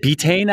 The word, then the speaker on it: betaine: It's funny, the betaine thing, the pronunciation is so bizarre. You know, when I learned. – Oh, is it betaine actually? – No, you're correct.